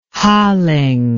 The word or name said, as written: halling